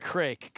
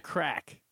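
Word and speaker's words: craic: Yeah, you're pronouncing it incorrectly. – Crike? – It's pronounced... – I can give you the pronunciation. – Yes, give me the pronunciation. – Crack.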